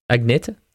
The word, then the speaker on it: Agneta: and uh, apparently, I, well, I just forgot how to say her name. – Agneta.